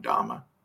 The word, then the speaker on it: Dhamma: Some people pronounce it, you know, you've got the two different pronunciations, Dharma and Dhamma.